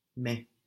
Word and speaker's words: mais: The word for but, it also ended A-I-S. – Mais. – Mais, good. M-A-I-S, but, mais. Jamais, jamais, J-A-M-A-I-S, is never, jamais.